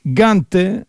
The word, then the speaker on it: Gante: et Gante avec un A et à la fin un E qui se prononce en espagnol.